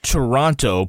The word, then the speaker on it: Toronto: Well, I think people who live in Toronto pronounce it Toronto.